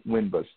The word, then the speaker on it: Winbush: So Wyman Winbush, W-Y-M-A-N, last name is W-I-N, B as in Baker, U-S-H.